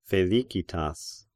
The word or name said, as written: Felicitas